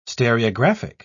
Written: stereographic

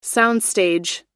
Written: soundstage

